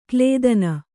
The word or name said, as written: ಕ್ಲೇದನ